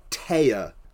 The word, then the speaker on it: Tea: Her name is Anzu, and they changed that to Teya, which is not a western name. – Mm. – T-E-A. It's spelled like T.